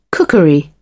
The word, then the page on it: cookery: British English